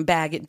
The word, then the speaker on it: Bagans: I believe it's Bagan, um, Bagan, B A G A N.